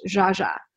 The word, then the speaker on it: Zsa: And so when it came to like finding names for my daughter, the first name on the list was Zaza, but I didn't realize her name's actually pronounced Zsa.